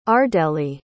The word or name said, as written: ardellae